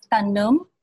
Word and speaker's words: tanem: A little bit differently, yes. Tanum. So it's a schwa, but it's still the same. Pretty much just, it's spelled with T-A-N-E-M, and E is a schwa.